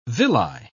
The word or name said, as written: villi